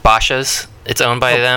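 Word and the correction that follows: Bashas': Oh, Bash's. It's spelled like Bauhaus, but with like a missing U.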